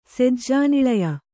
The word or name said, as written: ಸೆಜ್ಜಾನಿಳಯ